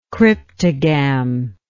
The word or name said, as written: cryptogam